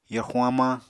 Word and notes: yorxuñ hamañ: apatani

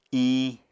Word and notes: iñ: apatani

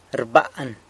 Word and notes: rban: sanlucasquiavini

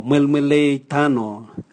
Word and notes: mwelmwelei tano: Raga

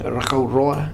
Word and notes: Rakau Roa: Futuna

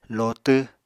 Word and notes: lotw: Apatani

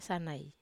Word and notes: sanai: Wamesa

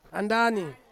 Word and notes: andani: Ansus

